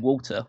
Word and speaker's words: Walter: and the crowd kind of ruined it because they keep shouting Walter, which is the correct way to pronounce his name.